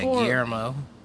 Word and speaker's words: Guillermo: Guillermo del Toro? – Yeah, Guillermo. – Oh, the L is silent.